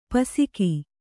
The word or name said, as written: ಪೊಸಗು